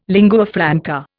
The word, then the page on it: lingua franca: Indian